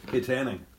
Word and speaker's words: Kittanning: Kittanning, like settlers of Katanning.